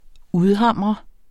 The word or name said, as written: udhamre